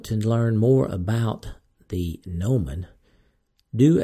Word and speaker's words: gnomon: Gnomon is spelled G N O M O N. That is G N O M O N. So it's not, the G is silent, so that's a little tricky.